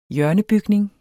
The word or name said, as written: hjørnebygning